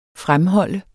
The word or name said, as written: fremholde